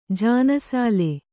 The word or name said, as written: ಜಾನಸಾಲೆ